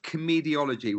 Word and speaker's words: Comediology: Tell us about your book, Comediology, which is a great title. Explain what comediology means, Chris. – Yeah, and thanks for pronouncing that right.